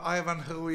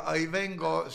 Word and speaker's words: Ivanhoe: Vaata, see vene keele hääldus, no Ivanhoe ja Ivengo, see on muster näide.